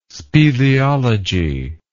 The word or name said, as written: speleology